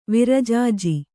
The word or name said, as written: ವಿರಜಾಜಿ